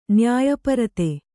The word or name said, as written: ನ್ಯಾಯಪರತೆ